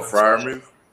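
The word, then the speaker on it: Freiermuth: Oh, Fryerman.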